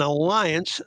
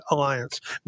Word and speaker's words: Alliance: We're back here with Russ Finch from Greenhouse in the Snow in Alliance, Nebraska. And by the way, the first segment, if you missed that, I learned how to say the word Alliance, not Alliance.